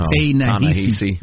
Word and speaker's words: Ta-Nehisi: Oh, Ta-Nehisi. – Yeah, Ta-Nehisi Coates.